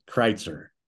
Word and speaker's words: Kreitzer: Trail Kreitzer.